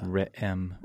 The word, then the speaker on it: Re'im: Re'im.